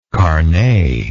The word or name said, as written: carnet